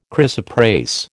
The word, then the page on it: chrysoprase: American English